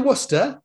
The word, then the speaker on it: Worcester: I was like, "No, it's definitely Worcester." She's like, "Yeah, but look how it's spelt."